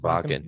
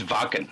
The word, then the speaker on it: Wacken: I'm pronouncing that probably wrong, right? – I think it's pronounced Wacken. – Wacken. – It's Wacken. – Wacken. – Yeah. – Yeah. So I don't know. They always pronounce W's as V's.